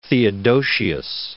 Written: Theodosius I

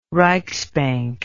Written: reichsbank

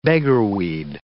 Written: beggarweed

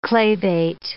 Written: clavate

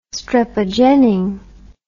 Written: strepogenin

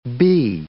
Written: b

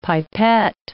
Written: pipet

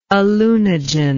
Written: alunogen

